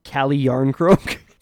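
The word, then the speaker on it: Calle Järnkrok: My actual pick for Nashville was Carl Jan Krok. – Cali Yarncroak.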